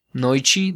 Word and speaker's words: Noite: Noichi dos mascarados. Okay. la T se pronuncia como la CH en español.